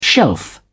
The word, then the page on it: shelf: British English